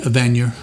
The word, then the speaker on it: Evanier: I thought it was Evanier. Is it Evanier? – Evanier, okay. I believe is how he pronounces it.